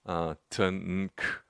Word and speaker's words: Tanakh: And the Tanakh is an acronym. It's made up of the Hebrew letters Tet, Nun, and Kaf. Tanakh. And you throw some vowel sounds in there and it's Tanakh. What do those letters stand for?